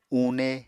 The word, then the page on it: une: Apatani